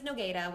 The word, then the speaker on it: Nogueira: So my last name is Nogueira, which is Portuguese. My dad's from Brazil. It has every vowel, N-O-G-U-E-I-R-A, so it doesn't look the way it sounds.